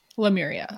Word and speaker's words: Lemuria: These are said to be connected to Lumeria too. Lemuria. I always say Lumeria, but it's Lemuria.